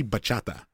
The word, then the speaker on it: bachata: I see bachata.